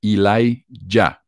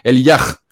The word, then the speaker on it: Elijah: En inglés, Elijah se pronuncia así, Elijah, pero se escribe Elija con H al final. Y la chica de Mafe lo pronuncia Elijah.